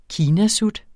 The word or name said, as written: kinasut